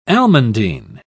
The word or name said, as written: almandine